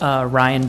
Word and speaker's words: Ryan: Ryan Brady. – Could you spell that? – Ryan, R-Y-A-N, Brady, B-R-A-D-Y.